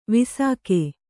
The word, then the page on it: ವಿಸಾಕೆ: visāke